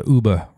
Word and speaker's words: Yuba: And the river was right there, the Yuba River, the Uba River. That's the way the Nisenan pronounce it. It's written Yuba, Y-U-B-A, on all maps and everything, but it's really Uba.